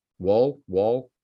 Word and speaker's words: Wuhl: I think it's pronounced W-U-H-L. Wool.